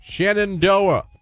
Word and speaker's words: Shenandoah: Shenandoah.